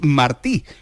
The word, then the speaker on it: Martí: Martí, con acento en la y como Martí, el que era jugador del, Sevilla.